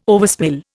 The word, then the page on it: overspill: Indian